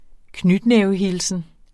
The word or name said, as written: knytnævehilsen